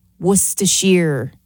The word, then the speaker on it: Worcestershire: Worcestershire is how you pronounce it.